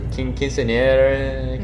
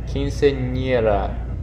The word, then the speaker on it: quinceañera: How do you pronounce it? Quinceanera? – No. Wrong. It's quince. – Quince. – Nie. – Nie. – Ra. – Ra. Quinceanera. – But a little bit more emphasis on the r.